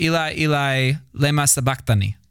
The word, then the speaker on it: sabachthani: Wait, no, it's sabachthani. That's how I said it. That's how it's... – Thank you. That's where the accent is on the Greek, so I was just trying to pronounce it.